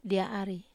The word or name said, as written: dia ari